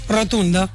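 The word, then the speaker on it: rotundă: ¿cómo se dice rotonda en rumano? – Rotunda.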